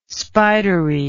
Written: spidery

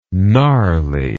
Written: gnarliness

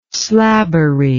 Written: slabbery

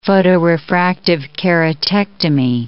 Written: photorefractive keratectomy

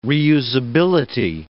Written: reusability

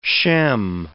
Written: Shem

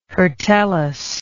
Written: hirtellous

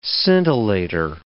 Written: scintillator

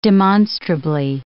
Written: demonstrably

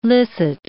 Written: licit

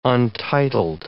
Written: untitled

